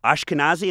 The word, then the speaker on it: Ashkenazi: I did the DNA test, and I found out that I'm 10% Ashkenazi. Am I pronouncing that correctly? – Yes, you are.